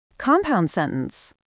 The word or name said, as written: compound sentence